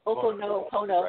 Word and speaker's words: Ho'oponopono: I can never, and I'm sure I'm not the only one that cannot pronounce this. Why do they have to make it's the Ho'oponopono. Ho'oponopono. – Right, it's okay. – Ho'oponopono. Yeah, please help me with that.